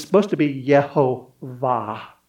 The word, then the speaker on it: Jehovah: So at least your grammar this morning, you're learning Hebrew and Greek this morning, we would look at Yahweh or Jehovah and the pronunciation of Jehovah, if we were staying legitimately to the letter and the T, it would be Yehovah, but they write Lord because they didn't want to take his name in vain.